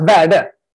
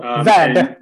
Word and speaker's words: Verde: Well, you can say it like it sounds, Verde. – Okay. – So, Verde.